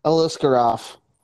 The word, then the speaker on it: Aliskerov: Aliskerov. – I think finished. – Uh, I haven't remember what the odds for him to win by finish, but I think Aliskerov wins by finish definitely.